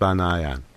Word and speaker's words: Banayan: Because in Armenian, it's not pronounced Banyan. It's pronounced Banayan. And we don't say it that way in the U.S. because, gosh, Banyan's harder. Banyan's easier. Now you just got a spelling problem. I'm not going to give you a pronunciation problem to go on top of it. And so we've always pronounced it that way.